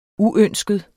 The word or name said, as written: uønsket